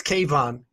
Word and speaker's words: Kayvan: it's Kayvan, with emphasis on Y. It's a Persian name, so yeah, it's a little bit yeah.